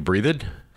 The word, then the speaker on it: Breathed: Because it looks like you'd say Breathed, but I believe that's the way he pronounces it.